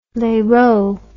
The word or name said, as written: lerot